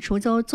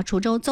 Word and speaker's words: 滁州: 这里锁定收听的是 FM95.0 滁州综合，刚刚那个字发音不准啊，滁州综合广播正在为您送上的是我们的天天有约，我是新月。